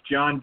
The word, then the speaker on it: Jon: For the listeners out there, the if you want to reach me through social media, I go by I'm listed under as John Decker Golf Instruction, and my first name is spelled J-O-N, so John Decker Golf Instruction.